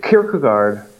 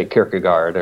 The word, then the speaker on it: Kierkegaard: So Kierkegaard, is as the Danish pronounced it. Well, I'll just say Kierkegaard or yeah. Either is fine.